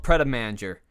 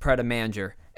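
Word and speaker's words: Pret a Manger: Let's sit down and talk about a place called Pret-a-Manger. Well, as we would learn, it's actually not pronounced Pret-a-Manger. It's pronounced Pret-a-Manger, which literally translates to ready to eat in French.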